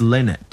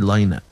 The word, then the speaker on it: Lynott: Phil Lynott and Paul McGrath. – It's Lynott. Don't ever say Lynott. Phil Lynott, lad.